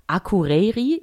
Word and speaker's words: Akureyri: Es gibt nämlich in Island, und zwar in Akureyri, ich hoffe, ich spreche es richtig aus.